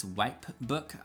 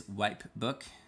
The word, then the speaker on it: Wipebook: I always fail to pronounce the P because I have West Coast accent, which tends to be a little bit on the lazy side with pronunciation. But it is Wipebook.